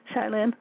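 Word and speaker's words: Shai Linne: If you like to hear his music and get his albums, his name is spelled S-H-A-I, that's Shy, and then Lynn is L-I-N-N-E, Shy Lynn.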